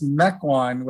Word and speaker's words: Mequon: Yes, Mequon, which is a suburb north of Milwaukee.